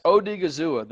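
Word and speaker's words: Odighizuwa: It's Odigazua, I believe. – Say again? Huh? – Odigazua, I think. – Yes, Odigazua, the defensive tackle out of UCLA.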